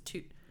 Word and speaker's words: Toot: And one guy on YouTube, he So I don't know the correct pronunciation, if it's Tut or Toot, but when you say his full name, how do you say it? It's Toot.